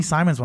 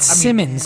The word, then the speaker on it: Simmons: It's Simmons. Man. I know he spells it like the way phonetically it should be Simons, but it is Anfernee Simmons. Simmons.